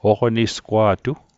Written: oqnisgwa'tu

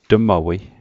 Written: tmawei

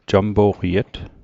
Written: jampo'qiet

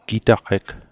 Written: gitaqeg

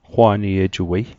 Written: qwaniejuwei

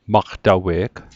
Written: maqtawe'g

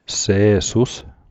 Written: Se'sus

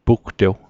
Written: pugtew

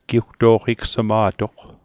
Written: giwto'qigsma'toq

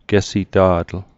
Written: gesita'tl

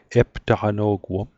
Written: eptaqano'guom

